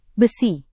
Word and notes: besi: indonesian

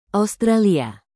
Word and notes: australia: indonesian

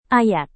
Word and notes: ayat: indonesian

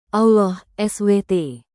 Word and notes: Allah SWT: indonesian

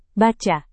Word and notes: baca: indonesian

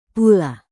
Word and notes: pula: indonesian

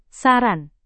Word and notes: saran: indonesian

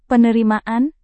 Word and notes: penerimaan: Indonesian

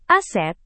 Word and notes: aset: indonesian

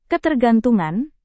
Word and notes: ketergantungan: indonesian

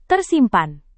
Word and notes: tersimpan: indonesian

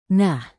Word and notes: nah: Indonesian

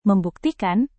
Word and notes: membuktikan: Indonesian

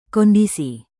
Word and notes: kondisi: Indonesian

